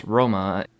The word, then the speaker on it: Roma: Okay, it's named after Rome, and Rome is actually pronounced Roma in Italian, which I kind of find interesting.